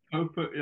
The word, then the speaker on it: Popert: Are we pronouncing it correct? Popert or Popert? – Yeah. Popert. Yeah. Everyone sort of gives it a go. Like, it gives me a giggle on the first tee.